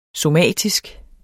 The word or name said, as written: somatisk